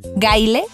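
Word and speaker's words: Gayle: Gail se escribe, el nombre del autor es e, G-A-Y-L-E, Gaile, Semac, Z, bueno, T-Z-E-M-A-C-H, Semac Lemon, como limón, pero lemon con doble M.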